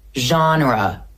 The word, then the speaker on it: genre: Now, the reason why this word is really complicated is because it comes from French. The original French pronunciation would be something like genre, but this word is now part of the English language as well, and it has kept some of its French characteristics.